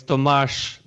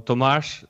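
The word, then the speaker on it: Tomáš: You said it almost correctly. So it's Tomas. It's like sh at the end. So Tomas Zaman.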